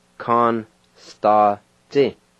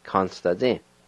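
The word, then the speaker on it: kanisdatsi: And the first I is silent in this word as well. It's pronounced like this. Ka-ni-s-ta-zi. Con-sta-zi. Con-sta-zi.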